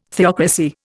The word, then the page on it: theocracy: Indian